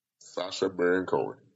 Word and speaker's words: Sacha Baron Cohen: So, how y'all feel about my boy, I can't pronounce his name, Sacha? That's his name? Cohen? – Sacha Baron Cohen.